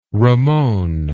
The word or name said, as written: Ramon